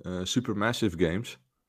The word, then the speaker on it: Supermassive Games: Supermassive Games. Zeg ik dat goed? Ja, volgens mij wel.